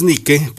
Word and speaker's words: Nike: Es Nike, porque es la diosa griega del deporte.